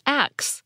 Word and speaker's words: ask: Next, we have a tidbit about the word that's spelled A-S-K. You'll usually hear this pronounced ask, but sometimes you'll hear it pronounced as axe, with the S and the K sound transposed.